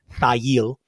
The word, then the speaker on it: Thayil: In the early 1980s, Kim Thayil, and that is how his name is pronounced, Thayil, Hiro Yamamoto, and Bruce Pavitt moved together from the Chicago suburb of Park Forest to Seattle.